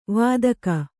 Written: ವೈದಿಕ

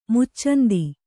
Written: ಮುಚ್ಚಂದಿ

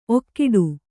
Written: ಔಕಾಟ

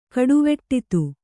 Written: ಕಡುವೆಟ್ಟಿತ್ತು

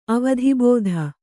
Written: ಅವಧಿಬೋಧ

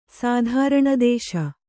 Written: ಸಾಧಾರಣದೇಶ

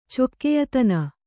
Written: ಚೊಕ್ಕೆಯತನ

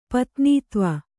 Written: ಪತ್ನೀತ್ವ

